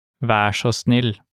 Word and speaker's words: vær så snill: In Norwegian, please is vær så snill.